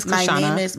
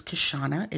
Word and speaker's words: Kishana: My whole name is Kashana. – My name is Kashana. It is spelled K-I-S-H-A-N-A.